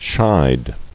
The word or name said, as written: Shied